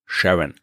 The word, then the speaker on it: Charon: Christi, der wollte ihm den Namen Charon geben, vor allem weil er damit seiner Frau Charlene eine Freude machen wollte, weil ihr Spitzname klingt ein bisschen so wie die amerikanische Aussprache von Charon. Sharon.